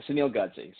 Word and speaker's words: Sunil Godse: Uh, Sunil Godse.